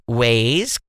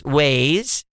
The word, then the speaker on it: Waze: Nir's a longtime listener to the show, been with the podcast for years, and he taught me how to pronounce Waze correctly. I was calling it Waz, it's Waze.